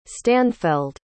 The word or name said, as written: stanfeld